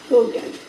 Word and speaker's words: Haugen: Her name is Lindsay Haugen, and that's H-A-U. Ooh, that's like H-A-U-G-E-N. Okay. I actually think it's pronounced Hogan. – Like Hogan's Heroes? – Like Hogan's Heroes, right.